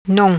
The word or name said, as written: Nom